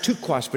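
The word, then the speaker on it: taqsh: It's pronounced tuquash, but it's T-A-Q-S-H.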